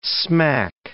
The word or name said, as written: smack